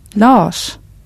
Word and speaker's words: Lars: Another point of pronunciation is how you pronounce R plus S. For example, in the word for person or the name Lars. – Person. Lars. – The R and S come together as one single sound.